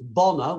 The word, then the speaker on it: Bonner: So Kyle Mayers at the other end, how do you pronounce the name? It's Bonner.